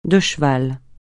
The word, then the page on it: de cheval: francais